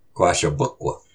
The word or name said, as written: Qáhshapuq